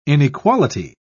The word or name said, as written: inequality